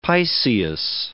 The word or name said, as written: piceous